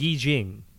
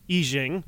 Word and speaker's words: Yi Jing: so, um, the uh, I Ching it is pronounced. Or Yi Jing. – Yi Jing.